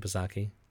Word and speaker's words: Psaki: Is that how you pronounce her last name, Psaki? – I assume the P is silent.